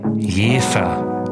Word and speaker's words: Jever: Eigentlich spricht man das aus Jefer. Also mit J-E-E-F-E-R.